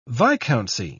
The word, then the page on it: viscountcy: British English